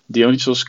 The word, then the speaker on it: Dionisos: Let's talk about your journey as Le Dionisos, which I butchered the name of in the intro. So Dionysos, hopefully I got that one right. – Perfect.